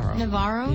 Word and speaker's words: Navarro: So how does he say his name? – Navarro? Yeah.